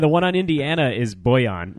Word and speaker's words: Bojan: Bojan. It's not like Bullion.